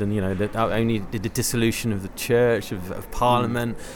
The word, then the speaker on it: Dissolution: Yeah, well, I call it Dissolution.